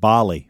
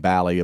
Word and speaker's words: Bally: I was calling it Bali for the longest. – Yeah, yeah. and the only way I knew how to pronounce it is because Skip with the Braves was saying Bally a bunch yesterday. – It makes sense that it's Bally because Rally is spelled the same way with an R.